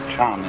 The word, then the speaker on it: Chan: Of the Sanskrit word jhana, pronounced chan in Chinese and Zen in Japanese, is unfortunately untranslatable in English.